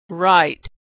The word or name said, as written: write